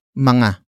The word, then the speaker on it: mga: But before that, we have mga. Mga. Mga. Mga is spelled as M-G-A.